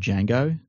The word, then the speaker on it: Django: It's because it's like saying the word Django. The D is silent.